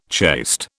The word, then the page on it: chaste: British English